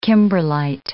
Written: kimberlite

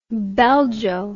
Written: belgo

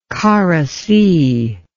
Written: Kara Sea

